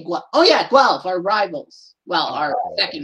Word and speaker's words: Guelph: Being from North America, I didn't know if it was Gulf or Guelph. – It's Guelph, isn't it? – Guelph. – Guelph, like elf, but with a gua. – Guelph.